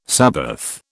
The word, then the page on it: sabbath: British English